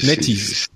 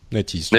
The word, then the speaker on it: NetEase: Il y a NetEase. Je je sais pas très bien comment on prononce. Euh, c'est, NetEase. – NetEase. NetEase, ouais, voilà.